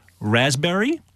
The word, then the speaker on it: raspberry: Raspberry is the silent S. – P. – Well, P. Sorry. – Silent P, right?